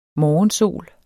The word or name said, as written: morgensol